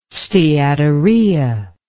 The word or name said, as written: steatorrhea